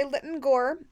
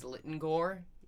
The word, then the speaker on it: Lytton Gore: The way Hugh Fraser says Lytton Gore, he puts the emphasis on the last syllable. So it Lytton sounds like Gore. Sounds like Egg Lytton. Egg Lytton Gore.